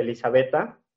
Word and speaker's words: Elisabetta: Muy bien, mis redes sociales son en Facebook, Elizabeta, tal cual, eh, se escribe mi nombre. Eh, con TIH, Elisabetta, Y en Instagram es Elizabeta DQ.